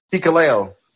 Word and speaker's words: Hikuleo: That's Hikuleo. How you say it. – Oh, okay. Hikuleo.